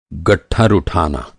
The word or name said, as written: गट्ठर